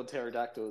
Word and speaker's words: pterodactyl: It's a dinosaur. No, because the P is silent. – Oh. – If you're wondering how to spell pterodactyl, it's got a P at the front.